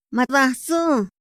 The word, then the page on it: ma datsu: Otomí